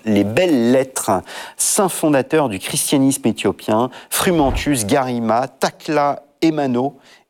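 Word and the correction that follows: Tekle Haymanot: et Evostateos. – Tekla Aimanot et Eostateos.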